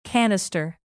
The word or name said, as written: canister